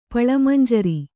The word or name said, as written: ಪಳಮಂಜರಿ